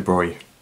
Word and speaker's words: Broglie: And his name was Louis de Broglie. Just be aware that his name is pronounced Broglie, not Broglie.